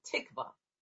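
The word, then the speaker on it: tikvah (תקוה): And the word is tikvah, T-I-Q-V-A-H is the phonetic pronunciation, tikvah.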